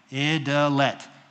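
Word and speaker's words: Idelette: Some of y'all don't say it right. Idalette. It's French. It was John Calvin's wife's name.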